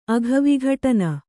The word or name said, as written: ಅಘವಿಘಟನ